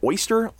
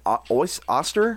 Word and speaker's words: Oster: Is it oyster? Oster? – Oster. – Oster. It was like Oster, yeah. Oster juicer.